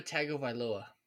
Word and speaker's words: Tagovailoa: In my head, Dak Prescott is the equivalent to Tua Tagovailoa.